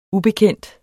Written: ubekendt

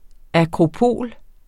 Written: akropol

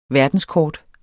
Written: verdenskort

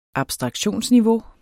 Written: abstraktionsniveau

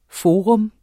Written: FORAENES

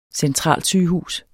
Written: centralsygehus

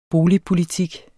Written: boligpolitik

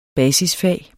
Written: basisfag

